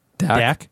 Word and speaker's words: Daq: It's like, the first word is D-A-Q. How do you say that word? – Dak. – Yeah. T-A-G-H? – T-A-G-H.